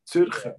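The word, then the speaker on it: Zürcher: You miss out the A. Zuricher. You say Zuricher.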